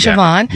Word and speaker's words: Siobhan: Yep, and Siobhan.